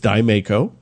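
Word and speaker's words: Diemaco: Some of the things we talked about on this podcast was how to pronounce the Colt subsidiary of Canada. It's Dymaco. – Ooh. – Yeah, Dymaker, Dymaco.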